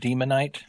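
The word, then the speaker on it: Daemonite: It's actually pronounced Demonite.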